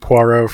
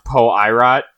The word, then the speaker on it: Poirot: Yeah, I don't think we're going to need Poirot for this one. – I believe his name is pronounced Poirot.